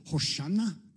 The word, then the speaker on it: Hosanna: Hosanna is a Hebrew word, and the actual pronunciation, as I understand it, is Hoshana, which means Lord save us.